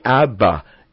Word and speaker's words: Abba: It's a simple, two-syllable, really, Abba. It's one word, Abba, but it's pronounced Abba by a child who perceives his father for the first time.